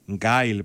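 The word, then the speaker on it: Guile: Γκάιλ, έστειλε μου Αντωνίσο Κυπριανού, ο ηχορήτης μας. Γκάιλ προφέρεται, ο Βαντάμ. – Γκάιλ, οκ, Γκάιλ, ευχαριστούμε.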